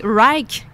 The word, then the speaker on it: Wrike: Il y a aussi Rike, qui s'écrit W-R-I-K-E, comme Write, mais avec un K, qui a l'air vraiment génial pour le travail d'équipe en entreprise.